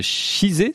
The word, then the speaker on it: Chizé: Vous êtes herpétologue, directeur de recherche CNRS au Centre d'études biologiques de Chizé, c'est comme ça qu'on prononce ? – Oui.